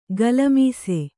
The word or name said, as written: ಗಲ್ಲಿಮೀಸೆ